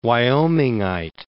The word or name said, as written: Wyomingite